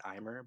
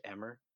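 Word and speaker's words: Aimer: One of my favorites is, I pronounce it Imer, but I think it's pronounced Emmer, like French, like Chitem.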